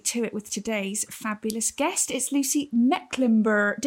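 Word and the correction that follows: Mecklenburgh: It's Mecklenburg. So just Mecklenburg. The H isn't there. Okay. Silent H. Mecklenburg.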